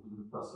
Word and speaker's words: Budapest: Prague is beautiful, Budapest is right up there. Go visit. So actually pronounced Budapest. Budapest. When I say it, I sound like Sean Connery.